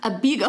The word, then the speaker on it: Abeego: A-B-E-E-G-O. – Mm-hmm. – It's emphasis on the B. – Yeah, Abego, I think.